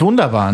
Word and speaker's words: wunderbar: Geschrieben wird das Wörtchen im amerikanischen so dass es die Amerikaner eben richtig aussprechen. V-U-N-D-A-B-A-R. Wunderbar. Mit V am Anfang.